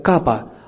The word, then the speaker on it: капха: Х не читается, поэтому правильно произносится как капа.